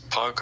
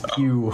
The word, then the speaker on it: Pugh: It's Florence Pugh. Yeah, I think you said Florence Puck or... – Oh, yeah, right. Oh, did I mispronounce it? Oh, Florence Pugh.